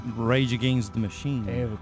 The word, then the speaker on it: Rage Against the Machine: Rage Against the Machine.